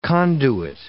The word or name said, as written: conduit